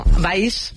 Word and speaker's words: Valls: Valls.